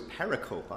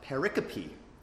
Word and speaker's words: pericope: And the word was pericope. I always thought that's how it was pronounced. Then I went back and realized it's a Greek word, and it is actually pronounced pericope.